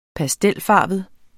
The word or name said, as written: pastelfarvet